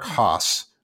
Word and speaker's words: Haase: All right, um, my guy this week is uh, Tigers catcher Eric Haase. Um, which looks like it may be pronounced Hasi, but it's Haas.